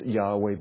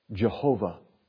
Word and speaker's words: Yahweh: We believe that the Tetragrammaton is pronounced Yahweh, but we can't be 100% certain because for many, many, many, many, many centuries, it wasn't pronounced at all. Most scholars agree that it's probably not pronounced Jehovah.